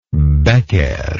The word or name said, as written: becquer